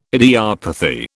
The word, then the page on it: idiopathy: British English